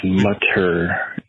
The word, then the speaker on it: Mutter: That's Mutter M-U-T-T-E-R.